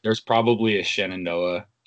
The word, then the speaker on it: Shenandoah: Yeah, Shenandoah. – Shenandoah. That's not hard. – People always get mixed up with Shenandoah. There's an extra en in there, and that's right, and it's spelled a little different.